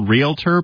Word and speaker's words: realtor: Catherine, thank you for calling us today here on Pastor's Perspective, and I know that she's a real realtor because she said realtor, not realtor. That's just one of my pet peeves when people mispronounce it. That's how it's supposed to be. – Realtor. – Realtor. – Yeah, like real estate, realtor, okay.